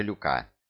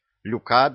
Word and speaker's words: Люка: — Нет, лучше Люка. Только не путай. Ударение на последнем слоге. По-моему, звучит достаточно аристократично. Короче, прошу любить и жаловать. Люка Бессони.